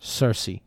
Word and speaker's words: Cersei: Was it Cersei? Is that how you say it? – Cersei.